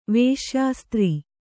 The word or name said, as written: ವೇಶ್ಯಾಸ್ತ್ರೀ